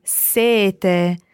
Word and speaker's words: sete: It's very similar, but number seven is double T, sete, and sete meaning thirst is single T. So when you say thirst, you can have a very long E, sete, while when you say number seven, sete, you need to be very short on your E to allow a very long T sound, sete.